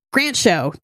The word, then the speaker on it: Grant Show: Grant Show. That is how they pronounce his name, which made me go through video interviews with him to check how he is introduced. It is just like the word.